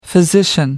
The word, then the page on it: physician: American English